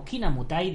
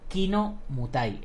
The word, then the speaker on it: Kinamutai: Kinamotai, también pronunciado correctamente como Kinamutai debido a la peculiaridad del idioma cebuano, pero erróneamente denominado Kino Mutai, erróneamente, por los artistas marciales occidentales que han escuchado mal el término.